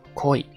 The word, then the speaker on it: 恋: 然后，嗯，这个好意呢，它读作 koi 啊，恋恋字呢，就是这个恋爱的恋呢，读作 koi 啊，读起来很相似。但是这个好意呢，它有一个拉长的一个声音，就是一个是 koi，一个是 koi 啊。